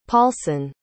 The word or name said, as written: Paulson